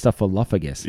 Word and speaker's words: Snuffleupagus: And she's like, no, it's not Upagus, it's Snuffleupagus.